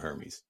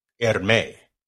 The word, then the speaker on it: Hermes: $349 just for the leather piece to go around the AirTag from Hermes. – It ain't that serious, man. – That's highfalutin, brother. – It's not that serious. Outside of and apparently the H is silent. Hermes.